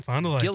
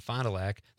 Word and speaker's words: Fond du Lac: She says, am I saying that right? – Gillies and Fond du Lac, yes. – Okay, Gillies and Fond du Lac.